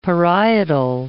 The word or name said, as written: parietal